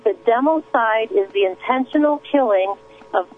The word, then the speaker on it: democide: It's called democide.